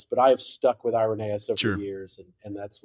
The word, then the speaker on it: Irenaeus: And he said, "How do we pronounce the name?" And it was hilarious. And we had one really bold, there was a really bold Irenaean scholar named Michael Slusser, mm-hmm. who's written a lot of articles on him. He stood up and he boldly pronounced, "It's Irenaeus."